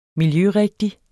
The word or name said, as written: miljørigtig